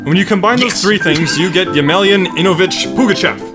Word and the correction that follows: Ivanovich: Ivanovich. – Ivanovich.